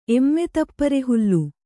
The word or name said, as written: ಎಮ್ಮೆತಪ್ಪರೆಹುಲ್ಲು